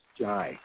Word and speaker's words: Jai: My name is Jai, J-A-I, pronounced J-Y, not J.